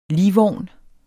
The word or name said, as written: ligvogn